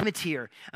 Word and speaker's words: Motyer: That doesn't look like a mater, but that's how you say it. So Alec Motyer.